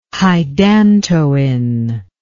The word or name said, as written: hydantoin